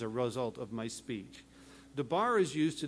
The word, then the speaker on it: dabar: It's translated from the word dabar. D-A-B-A-R is the way it's spelled, dabar.